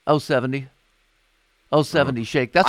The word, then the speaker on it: 070 Shake: Yeah, it's 070, but I think it's pronounced 070 Shake, who also has another new track that just came out a couple days ago.